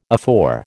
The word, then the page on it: afore: American English